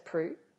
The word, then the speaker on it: Proulx: And so it's a list of Annie Proulx's stories. Is it pronounced Proulx? – Yeah, E-Annie Proulx.